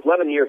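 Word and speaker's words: Lemonier: Is it Corey Lemonnier of Lemonnier. – Auburn? Let's talk about these two guys a little bit. – Yeah. And it's funny because Lemonnier, or it was Lemonnier for the whole time he was at Auburn.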